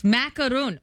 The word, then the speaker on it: macaron: Macaron. Macaron. The French pastry. The French pastry. – Why is it all of a sudden three syllables?